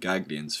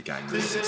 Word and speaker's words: Ganglians: I believe they're pronounced. I've been calling them Gaglions for a long time, but uh, looking at it, it's obviously Ganglians.